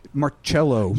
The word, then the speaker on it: Marcello: Marcello.